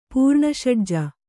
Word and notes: ಪೂರ್ಣಷಡ್ಜ: kannada

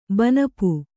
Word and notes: ಬನಪು: banapu

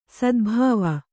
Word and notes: ಸದ್ಭಾವ: sadbhāva

